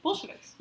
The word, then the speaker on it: Bolsheviks: How do you say that word? Bolsheviks? – Bolsheviks.